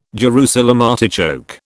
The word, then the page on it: Jerusalem artichoke: British English